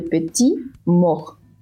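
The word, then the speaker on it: petite mort: Lo único que sí les puedo decir es que, y la única regla gramatical que recuerdo en estos momentos, es que cuando una palabra tiene terminación RT al final, no se pronuncia la T, a menos que tenga una E. Pero bueno, información inútil. Se, ya, se pronuncia le petit mort, así, como más gutural, la mort.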